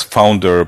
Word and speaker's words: FNDR: And for the viewers, say it as Founder, but it's spelled F-N-D-R.